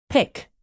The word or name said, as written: pick